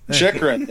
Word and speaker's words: Chychrun: Chychrun, you gotta, if you're gonna insult the guy, at least say the name properly. – Chychrun, yeah, jeez.